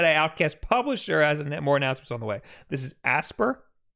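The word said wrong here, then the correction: Aspyr: Yes. Aspire. – Aspire. I'm so sorry, Will. – I think that's how it's pronounced.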